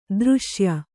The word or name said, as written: ದೃಶ್ಯ